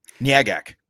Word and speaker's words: Nyagak: It is very difficult to pronounce, but it is spelled N-Y-A-G-A-K. – Nyagak. – Yes. Right? – I think that's how they said it. Nyagak or Nyagak.